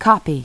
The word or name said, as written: copy